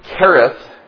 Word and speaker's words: Cherith: In fact, the very word Cherith, means, the H is silent in that, means to separate or to cut away.